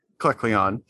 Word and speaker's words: Kecleon: Kecleon? – Yeah, Kecleon. That's it.